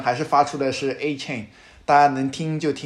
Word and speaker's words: Achane: 是两个 RB 啊， Raheem Mostert 和 Devon Achane，我都不知道，因为 Adam Schefter 和那个他们礼拜一的时候，因为他拿了四个 touchdown 之后，他自己跟联盟说他的那个名字的发音大家都给他发错了，因为他们原来官网上面是说 Achane 对吧，一个像一个锁链一样 Achane，但他说他的发音应该是 Achane，我也不知道 Okay.